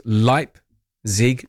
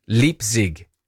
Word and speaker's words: Leipzig: The German pronounced the second vowel, so it's Leipzig, not Leipzig.